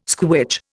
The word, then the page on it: squitch: Indian